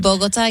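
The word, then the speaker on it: Bogotá: So police in Barcelona said that the man had flown in from Bogota. Is that how you say it? – Bogota, yeah.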